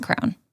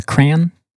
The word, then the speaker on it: crayon: The word crayon looks a lot like the word canyon. I pronounced it that way just to draw the letters that they have in common. But it's a crown. It's a crown. – Crown. A crayon? – Yeah, I think. – Cranberries. Like, Happy Thanksgiving. – I will say over the years, my pronunciation has devolved a little bit from crown to crayon.